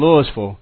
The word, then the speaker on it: Louisville: And you know someone from Louisville because they pronounce it right.